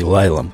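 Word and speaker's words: Lisle: Между нашей деревней и Лайлом, который пишется как Лисел, но читается как Лайл.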